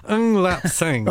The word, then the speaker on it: Ng Lap Sang: Nip Lung Sang? – No. You pronounced that wrong. Ng Lap Sang.